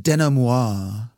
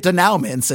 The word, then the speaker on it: denouement: This is also where I get to be all bougie and remind you that it's pronounced denouement. – The fuck? Really? – Denouement is the, we learned this in film school, dude. I totally remembered this. We were all calling it denouements.